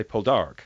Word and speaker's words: Poldark: But now all the characters, when they say the word, say the surname, say Poldark. – Oh, really? – With the emphasis on the second syllable.